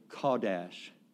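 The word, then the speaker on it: Kadosh (קדוש): We would pronounce it Kadosh. K-A-W. D-A-S-H, Kadosh.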